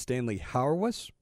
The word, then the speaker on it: Stanley Hauerwas: I think this guy's name is pronounced Stanley Hauerwas.